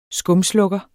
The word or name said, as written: skumslukker